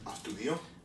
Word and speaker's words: Astudillo: How do you say it? – Astudillo. – Astudillo. – Astudillo. – So it's like studio with an A.